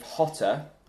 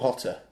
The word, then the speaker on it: Jota: Mark Canary asks, if Hotter, this is the guy who's just gone to Birmingham from Brentford, Spanish guy, very good. – Jota or Hotter? – I think it's Hotter.